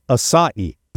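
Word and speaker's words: açaí: This is the Barry ACAI. The I don't know how to say this. – Acai berry? – No, it is pronounced acai.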